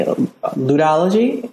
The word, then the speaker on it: ludology: Well, that's good because in this book, Cultural Code, you talk about you use a specific methodology, ludology, if I'm pronouncing it correctly.